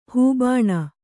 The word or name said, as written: ಹೂಬಾಣ